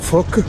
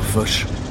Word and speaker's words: Foch: Dites-moi, comment faut-il prononcer le nom de votre maréchal ? Foch ou Fock, comme en allemand ? – Il faut dire Foch. On ne prononce pas à l'allemande, ici.